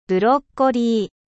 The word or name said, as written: brocoli